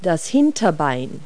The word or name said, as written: hind leg